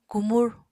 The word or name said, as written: kumur